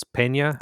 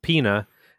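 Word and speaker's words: Peña: Colt and I have a last name that little N is supposed to have a tilde over it. It's Pena. – They call us the N-word. – If there's no tilde, people just pronounce it Pena.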